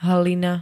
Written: hlina

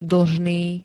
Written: dlžný

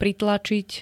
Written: pritlačiť

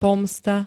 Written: pomsta

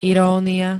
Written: irónia

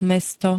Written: mesto

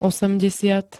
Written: osemdesiat

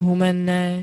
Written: humenne

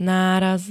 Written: náraz

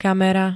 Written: kamera

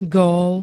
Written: gól

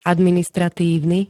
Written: administratívny

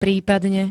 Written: prípadne

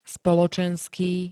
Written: spoločenský

